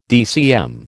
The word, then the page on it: DCM: American English